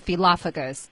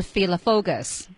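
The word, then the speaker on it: phyllophagous: 刚才傅老师读的是 philophagus，是英英的读法啊。美英的读法呢是 philophagus 啊，philophagus，philophagus、都可以，两种读音都可以。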